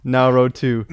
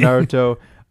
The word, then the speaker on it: Naruto: Also, Michael, now row two. – Now row two. – I came because I saw the word anime on the thumbnail. – Yeah. – That was the worst pronunciation of Naruto.